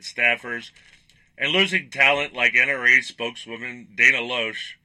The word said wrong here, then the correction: Loesch: I'm sorry, Lash. It's spelled Loesch.